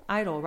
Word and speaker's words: idyll: Idol? Idol, right? I-D-Y-L-L? – Yeah.